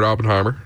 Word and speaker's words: Oppenheimer: Is it Oppenheimer? I want to say it right. – Yeah, Robert Oppenheimer.